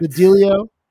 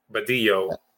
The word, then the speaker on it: Badillo: Badillo. – Badillo. – Badillo. – Yeah, if you're Spanish, the two L's are easier to pronounce.